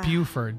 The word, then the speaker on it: Buford: You're saying it wrong. It's Buford. Buford, Georgia. It's up 85.